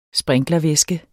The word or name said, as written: sprinklervæske